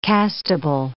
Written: castable

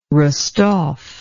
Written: rostov

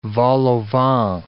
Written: vol-au-vent

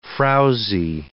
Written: frowzy

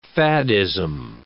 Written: faddism

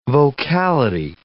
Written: vocality

